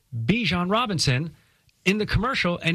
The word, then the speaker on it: Bijan: And we got that at the tail end of last season when Bijan Robinson went from Bijan to Bijan.